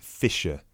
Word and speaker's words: Fischer: It's Mr. Bennett Fisher. – Okay. Sorry, how do you spell your surname? – It's spelled F-I-S-C-H-E-R.